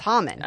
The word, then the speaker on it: Haman: It's Haman. – Oh. Haman. – Haman's the one that decides this. – Yes. – Right. – Because Mordecai refuses to bow to Haman or Haman, however you've heard it pronounced.